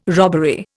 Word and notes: robbery: Indian

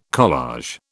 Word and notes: collage: British English

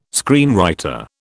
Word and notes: screenwriter: British English

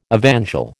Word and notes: evangel: American English

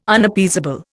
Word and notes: Unappeasable: Indian

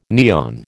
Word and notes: neon: American English

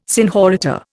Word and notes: senhorita: Indian

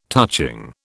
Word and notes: touching: British English

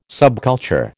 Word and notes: subculture: American English

